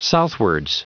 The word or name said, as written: southwards